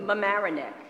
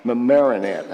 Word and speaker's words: Mamaroneck: She's transferring in from a church, a Methodist church in New York, Madamasset. Did I say it right? – Mamaroneck. – Mamaroneck. Okay.